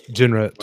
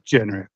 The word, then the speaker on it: Genret: As always, and with me, my homies, Mark Ambulmeyer, Rudy Lara, and our guest host, Joey Genretti. What's going on? Or is it Genret? – Genret, two syllables. – Genret. There we go.